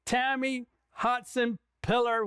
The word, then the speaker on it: Tammy Hotsenpiller: Tammy Hotsenpiller, welcome to the program.